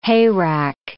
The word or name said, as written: hayrack